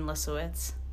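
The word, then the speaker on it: Lisowicz: It's just Nadine Lisowitz. – Ooh. – At Nadine Lisowitz. – Why don't you spell that last name out? – L-I-S.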